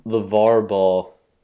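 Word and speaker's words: LaVar Ball: Is it LeVar Ball? – LeVar Ball.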